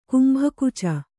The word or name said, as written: ಕುಂಭಕುಚ